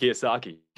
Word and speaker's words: Kiyosaki: Kiyosaki. Mm-hmm. Uh, how do you say his last name? – Robert Kiyosaki.